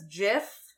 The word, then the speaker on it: GIF: It's a soft G. – The guy who created it said it was a soft G. I don't care. It's GIF, graphics. Like GIF.